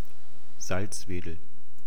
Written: salzwedel

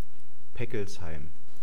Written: peckelsheim

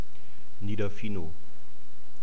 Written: niederfinow

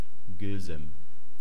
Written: Gilzem

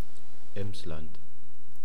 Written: Emsland

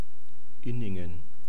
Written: inningen

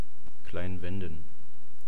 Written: klein-wenden

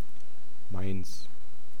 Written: mainz

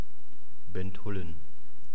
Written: Benthullen

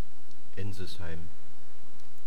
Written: Ensisheim

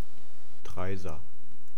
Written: Treysa

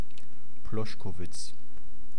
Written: ploschkovitz

